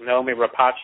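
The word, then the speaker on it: Noomi Rapace: but Naomi Rapace is how you pronounce her name, who played Elizabeth Shaw.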